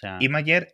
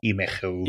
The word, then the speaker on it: Imgur: Imager es la versión bien pronunciada de lo que todo el mundo normal conocemos como Imgur.